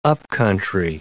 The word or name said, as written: up-country